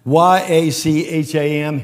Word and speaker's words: Yacham: This word conceived in the Hebrew language, it appears about six times in the Old Testament only, and it, and, and, and it sounds something like Y A C H A M, Yacham. That's how you say it, Yacham.